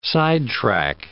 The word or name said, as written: sidetrack